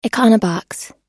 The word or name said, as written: econobox